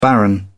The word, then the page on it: baron: British English